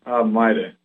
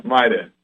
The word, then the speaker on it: Maire: Maida. – Maida. Say it again. – Maida. – Maida. – Yeah. – It's spelled M-A-I-R-E, but it's pronounced Maida. – Yeah. – Like M-Y-D-A.